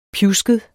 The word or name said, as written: pjusket